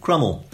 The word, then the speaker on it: Cromwell: And incidentally, gave me another clue to the pronunciation of Crummel. – Right, okay. – Because on more or less day one of the rebellion, some rebels in Dent shouted that we would crumb him and crumb him till he was never so crumbed.